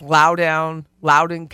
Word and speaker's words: Loudoun: It's Loudoun County.